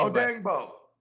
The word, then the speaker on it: Odeyingbo: This dude, Deyo Adeyingbo, I'm not even sure how the hell to pronounce his name. – Odeyingbo. – Yeah, there you go.